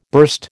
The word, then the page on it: burst: American English